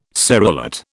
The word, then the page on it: serrulate: British English